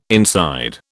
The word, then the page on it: inside: British English